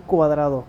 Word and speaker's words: Cuadrado: Pues a mí me pueden seguir como Jeriel Cuadrado. Se escribe con J, Jeriel Cuadrado. Cuadrado como la figurita. El cuadrado, pero el apellido.